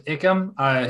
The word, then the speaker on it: Ikem: Did I say that right? – I believe it's Ikem.